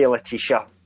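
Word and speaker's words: Letitia: British would be, we would say Leticia.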